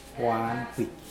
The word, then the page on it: wahnpwij: Mokilese